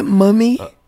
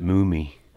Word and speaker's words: Mumy: Isn't it Mummy? – I think he pronounces it Mumy, actually.